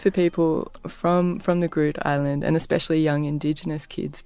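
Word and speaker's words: Groote Eylandt: Groot Island? – So the way it's actually Dutch, but for yeah. Great Island. But the way to pronounce it in Dutch is Groote Eiland.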